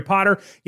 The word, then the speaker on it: Potter: My name is Jerry Potter. Yes, it rhymes with the boy wizard,